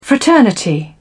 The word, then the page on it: fraternity: British English